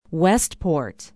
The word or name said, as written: westport